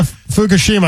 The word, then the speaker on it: Fukushima: Yeah, Fukushima.